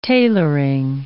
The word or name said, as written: tailoring